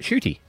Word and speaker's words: Ncuti: His name is spelled N-C-U-T-I. – Pronounce it. – You pronounce it Shuti. – Oh. – Shuti Gatwa.